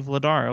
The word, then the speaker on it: Vladar: Oh, it's actually Vladar. Okay, shout out to Kevin, our pronunciation guru.